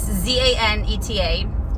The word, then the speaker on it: Zaneta: I'm like, sometimes I don't even bother because I'm like, yeah, it's Zanetta.